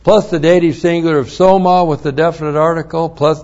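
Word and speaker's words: Soma (σῶμα): S long O-M-A. Soma is the Greek word for body.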